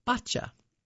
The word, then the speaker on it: bocce: It could be bocce, B-O-C-C-E, or bocce, B-O-C-C-I-E, or bocce, with B-O-C-C-I, with the preferred pronunciation being bacha.